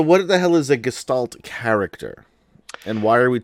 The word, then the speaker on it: gestalt: What the hell is a gestalt? Am I pronouncing that right? – You're pronouncing that correct.